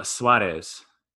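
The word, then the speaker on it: Suárez: Eugenio Suarez. – Thank you. – You wouldn't have said the first name right. Right? It's tough. – Eugenio. He's also a friend of mine, and in America, like Suarez is the Americanized.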